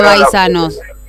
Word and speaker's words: Baisano: Esa B hay que pronunciarla entre B y P, ¿entendés? – ¿Qué significa Baisanos? – Y es la pronunciación de los inmigrantes árabes.